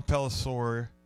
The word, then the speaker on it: Pelissero: Yeah, Ryan Clark, Carl Ravitch, Tom Pelissero.